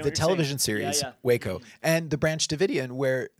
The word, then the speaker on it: Waco: Waco. – The show. Space. Waco. – You didn't say it like that. – Oh, really? – You said, it made me think of Showeco. Like, what's Showeco? – Showeco, it's an interesting drink.